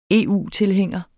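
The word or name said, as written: EU-tilhænger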